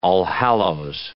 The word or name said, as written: Allhallows